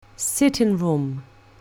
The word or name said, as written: sitting room